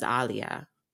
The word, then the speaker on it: Alia: So my name is properly pronounced as Alia, mm-hmm. if you want to get like real with it. But mm-hmm. we say in the broad American, right? Yeah. We say Alia, but even in my household, we say Alia.